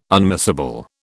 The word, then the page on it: unmissable: British English